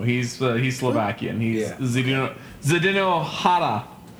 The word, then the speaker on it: Chara: Just like Zdeno Chara, Zdeno Hara.